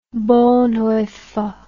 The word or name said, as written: Bonhoeffer